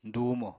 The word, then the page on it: dùumo: Apatani